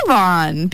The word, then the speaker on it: Zevon: And DJ Grand Banter did text me about this, you know, the correct pronunciation. So it's Zevon, because he wrote down a billion E's.